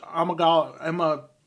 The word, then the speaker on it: amygdala: And then the second one was a damaged amygdala. – Spell it. – A-M-Y-G-D-A-L-A.